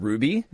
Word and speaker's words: RWBY: It's called Ruby. – Yeah. – But it's spelled weird. It's like R-W-B-Y.